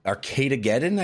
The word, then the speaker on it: Arcadegeddon: Uh, Arcade Arcadegeddon, I guess it's pronounced Arcadegeddon, Arcadegeddon.